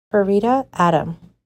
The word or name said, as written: Farida Adam